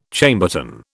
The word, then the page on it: Chambertin: British English